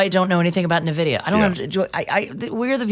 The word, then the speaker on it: NVIDIA: The company is pronounced NVIDIA.